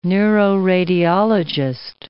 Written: neuroradiologist

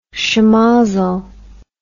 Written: shemozzle

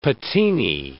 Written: patinae

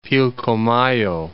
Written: Pilcomayo

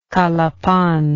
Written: calapan

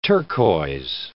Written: turquois

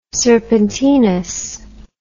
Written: serpentinous

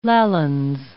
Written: Lallans

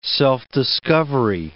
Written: self-discovery

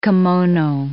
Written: kimono

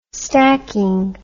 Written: stacking

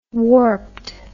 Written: warped

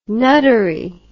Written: nuttery

